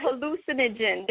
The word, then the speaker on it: hallucinogen: This is, you see, I gotta pronounce, my pronunciation is off. It's a hallo, halluction. Okay, how do you pronounce it? – All right, you pronounce it. – It's a hallucinogen. There we go.